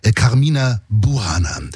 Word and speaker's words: Carmina Burana: Und sein bekanntestes Werk, das ist wahrscheinlich die szenische Kantate, so muss man die aussprechen, Carmina Burana. Ein großartiges Stück Musik.